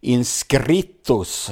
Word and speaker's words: inscritos: Perfekt uttal. – Ja, ja, ja, precis. – Inscritos.